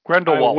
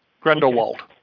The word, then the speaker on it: Grindelwald: Grindelwald. – I wouldn't know. – Grindelwald, who was introduced in the last two movies there.